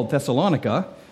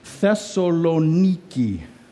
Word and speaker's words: Thessaloniki: and they named this really cool city that you would call Thessalonica. Well, in Greek, it's Thessaloniki. Basically, the name pronounces and proclaims victory over Thessaly.